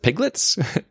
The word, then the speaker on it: pglets: And what's kind of fun is the little packages are called pglets, L-E-T-S, so it's like you kind of pronounce it piglets, and they're Postgres applets.